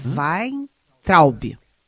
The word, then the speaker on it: Weintraub: E entra um perfil é, também, assim, é, muito, muito, muito definido pela ideologia, que é o também professor, professor universitário, Abraham Weintraut. Como é que é? Hum? Weintraub. – É, eu acho que eu falei certo. Weintraub.